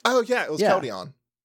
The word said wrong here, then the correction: Keldeo: Keldeo? – No, just Keldeo. – Keldeo, no N. – Okay. – Keldeon is my favorite Eeveelution. – No N, accent on the O.